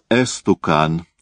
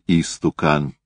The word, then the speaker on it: истукан: Он говорил «эстукан» вместо «истукан», «особенный» вместо «особенный», «турк» вместо «турок».